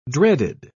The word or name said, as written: dreaded